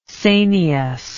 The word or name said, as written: sanious